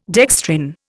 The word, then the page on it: dextrin: Indian